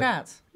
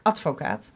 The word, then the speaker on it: Advocaat: Advocaat. – Yeah. – This is the Dutch pronunciation. – Yeah. – Advocaat.